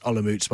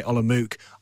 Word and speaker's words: Olomouc: It is pronounced Olomouc, by the way. Everyone else will say Olomouc.